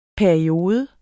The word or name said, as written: PERIODES